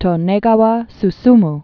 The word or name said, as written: Tonegawa